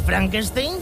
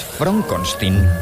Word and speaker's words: Frankenstein: ¿Doctor Frederick Frankenstein? – Soy yo, pero es Frankenstein. – ¿Me está tomando el pelo? – No, se pronuncia Frankenstein.